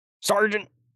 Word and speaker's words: Sargent: Sargent! Also doesn't spell it like how you'd think it would for Sargent, so...